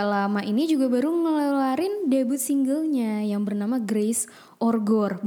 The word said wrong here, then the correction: Grace and Gore: benar gak sih cara bacanya gitu? Grace and Gore.